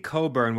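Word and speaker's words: Cockburn: Kofi Coburn, which I'm surprised it's pronounced Coburn, but after you see the guy, if he told you his name is pronounced a certain way, you would go, yes, sir, whatever you want, sir.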